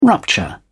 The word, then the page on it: rupture: British English